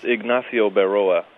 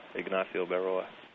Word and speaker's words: Ignacio Berroa: Oh, that's Ignacio Berroa. Ignacio Berroa.